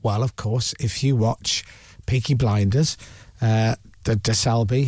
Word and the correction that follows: Shelby: he's in charge, isn't he? Something like that. – Shelby, yeah, it's close.